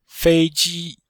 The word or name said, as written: fei1ji1